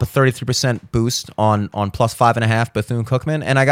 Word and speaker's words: Bethune-Cookman: Bethune-Cookman.